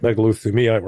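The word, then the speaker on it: megalothymia: It's this concept called megalithemia. Am I saying that right? – Megalithemia, right. – Megalithemia, okay.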